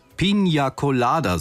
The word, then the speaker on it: Piña Colada: Man hätte es vielleicht der Band vorher sagen sollen, dass das Getränk eigentlich korrekt heißt Piña Colada, so wird's ausgesprochen, aber als der Song erschienen ist, da ist es möglicherweise so vielen gar nicht aufgefallen.